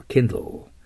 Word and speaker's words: Kindle: Kindle。 Light とは違います。 Light というのは火がつくのが当たり前のものに火をつけるのが Light です。 Light Candle とか言います。 Kindle というのは火を灯すというけども、どちらかといえば今のように希望の火を灯すとか、ね、感情などを燃え立たせるという時によく使いますね。はい、発音してみましょう。 Kindle。最後 L の発音ですよ。